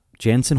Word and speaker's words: Jansen: Uh, Yannick or uh, Janet uh, Jansen Harkins. I can never get his name right. I'm always thinking of the soft J for some reason because of all the European players.